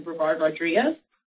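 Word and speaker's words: Brevard-Rodriguez: My name is Dr. Eliza Brevard Rodriguez.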